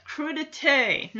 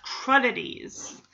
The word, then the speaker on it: crudité: Yeah, crudité, not crudities. And that's how I learned. I'm like, oh yeah, they've been pronouncing it wrong this whole time.